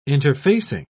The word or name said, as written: interfacing